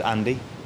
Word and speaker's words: Andy: My name's Andy. – How do you spell it? – A-N-D-Y.